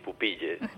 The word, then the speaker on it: Pupille: Ho he dit bé, això? Ho he pronunciat bé o no? – Sí, Pupille. – Ah, ho dic ben bé, Pupille.